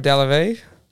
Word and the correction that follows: Delevingne: It's Cara. – Cara Delevingne. – It's Delevingne. I know the last name.